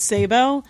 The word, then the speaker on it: Sabo: It's Sabo. But I think it might be more legit because I'm Hungarian and it used to be S-Z-A-B-O.